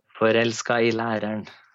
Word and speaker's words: Forelska i læreren: How do you pronounce this? – Forelska I læreren. – There we go.